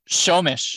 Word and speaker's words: Snohomish: Can you guys pronounce the town name that you're in? – Snohomish. – Snowman. – Snowman. No. Snowman-ish. – That's not even close.